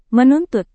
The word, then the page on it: menuntut: Indonesian